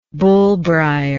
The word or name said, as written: bullbrier